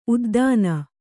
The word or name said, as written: ಉದ್ದಾನ